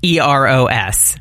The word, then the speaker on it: Eros: You're not mispronouncing Eros, it's Eros.